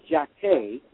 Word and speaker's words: Jacquet: But the name Jacquet, that's how you say it in French. You don't pronounce the T. Like many French words, you don't pronounce the final consonant.